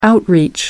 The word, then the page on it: outreach: American English